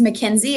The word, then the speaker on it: Makynzie: So my name is a little tricky to spell, but my first name is Mackenzie. It's spelled M-A-K-Y-N-Z-I-E.